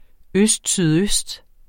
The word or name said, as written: østsydøst